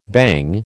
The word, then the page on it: bang: American English